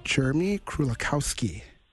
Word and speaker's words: Krulikowski: Jeremy Krulikowski.